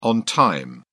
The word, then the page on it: on time: British English